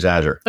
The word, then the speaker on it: Azure: But the way to remember it is say, as you're going to the cloud, use Azure.